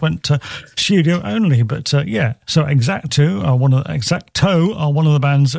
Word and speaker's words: Exacto: Anyway, played a song from this band last month, an XTC tribute band in Australia, Exacto, X-A-C-T-O, Exacto is how you pronounce it.